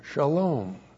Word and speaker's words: shalom: S-H-A-L, long O-M, shalom.